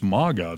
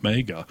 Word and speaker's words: MAGA: And by the way, my dad again the other day was like, it's MAGA, it's not Mega. I'm like, it's a made-up word, so there is no actual correct way to say it.